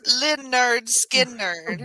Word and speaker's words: Lynyrd Skynyrd: Pronounced Lynyrd Skynyrd. They tell you right there how to say it.